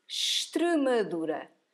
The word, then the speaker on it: Extremadura: So you don't pronounce the I, you say Extremadura.